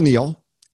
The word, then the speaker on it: Neal: like K-N-I-E-L, and boy, I can tell you. So it's the real Neil, N-E-A-L, and there's a few Schaefers of us out there in sales and marketing.